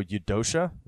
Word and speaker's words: Eudoxia: His first wife's name was, and I hope I'm pronouncing it right, it's spelled Eudoxia. So Eudoxia, is that how you'd say that, I think? – I don't know how else you would pronounce it. – Sure. So we're going to call her Eudoxia.